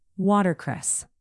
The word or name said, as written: watercress